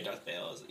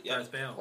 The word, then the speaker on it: Darth Bale: Like Christian Bale. – Yeah. Let me see. Yeah, spell it. Oh, wait. – B-A-L-E. – Darth Bale. – Darth Bale.